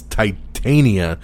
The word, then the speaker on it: Titania: I think I said Tatiana, but it's Titania. I've never heard of this character, honestly, before this news broke, because I'm not a big She-Hulk fan. But Titania is the name of the character, and that's who she will be playing in the upcoming MCU show.